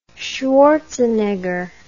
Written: schwarzenegger

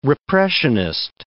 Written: repressionist

